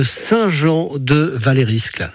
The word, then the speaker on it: Saint-Jean de Valériscle: Jean de Saint-Jean de Valériscle. C'est ça? Je me trompe pas dans la prononciation, oui. – Jean? – Oui, oui, c'est très bien, c'est ça.